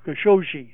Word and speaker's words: Khashoggi: the murder of Khashoggi is the pronunciation.